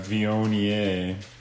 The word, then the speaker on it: Viognier: And today we're drinking a Viognier from France.